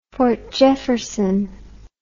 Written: fort jefferson

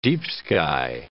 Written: deep-sky

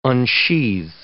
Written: unsheathe

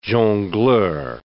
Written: jongleur

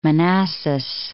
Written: Manassas

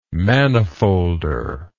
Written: manifolder

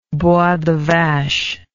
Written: bois de vache

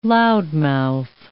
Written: loudmouth

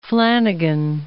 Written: Flanagan

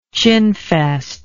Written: chinfest

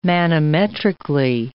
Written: manometrically

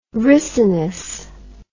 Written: ricinus

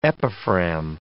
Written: epiphragm

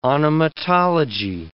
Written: onomatology

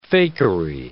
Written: fakery

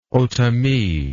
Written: otomi

